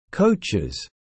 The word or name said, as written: coaches